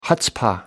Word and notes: chutzpah: American English

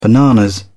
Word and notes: bananas: British English

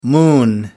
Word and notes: moon: American English

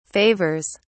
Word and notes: favors: British English

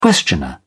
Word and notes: questioner: British English

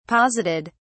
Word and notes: posited: British English

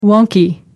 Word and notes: wonky: American English

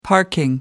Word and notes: parking: American English

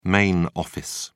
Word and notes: main office: British English

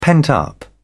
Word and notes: pent-up: British English

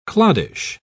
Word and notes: cloddish: British English

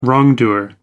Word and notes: wrongdoer: American English

